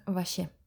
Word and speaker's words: вообще: В разговорной речи мы иногда его произносим как «вообще» и даже пишем так в мессенджерах.